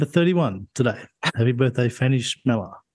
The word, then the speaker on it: Chmelar: And how would you pronounce this? I'm trying to work out how to pronounce this one. It's a surname. C-H-M-E-L-A-R. How do you say that? C-H-M-E. Hey? – Oh, no. – C-H-M-E-L-A-R. – Schmeller. – Schmeller.